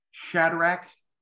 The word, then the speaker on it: Shadrach: I'm going to hold off talking about Shadrach. That's how you pronounce his name.